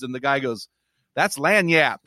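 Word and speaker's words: lagniappe: I learned that word Lanyap in Louisiana. That's how I could pronounce it, so pretty proud of myself there.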